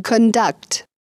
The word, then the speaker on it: conduct: Займемся теперь словами, которые играют роль глагола или существительного в зависимости от ударения в слове. В случае с глаголом ударение падает на второй слог. – To conduct.